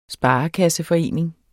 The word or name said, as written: sparekasseforening